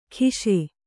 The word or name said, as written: ಖಿಶೆ